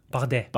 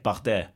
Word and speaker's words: Bardet: Ja, Bardet. – Bardet. Bardet. Tour de Bardet. Romain Bardet de Tour de France. – Mm. Laten we, dat zo, laten we het voortaan zo uitspreken.